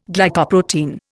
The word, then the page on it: Glycoprotein: Indian